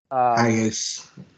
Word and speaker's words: Hiace: So it's the commuters, it's the Hiace, however they pronounce it. – Hiace. That's how we did it.